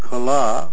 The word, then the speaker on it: Kala: The word Kala, with a long A at the end, means any practical art or any mechanical or fine art.